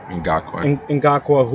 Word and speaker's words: Ngakoue: Ngakoue, who's on the franchise tag, doesn't want to be in Jacksonville.